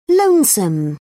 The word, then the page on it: lonesome: British English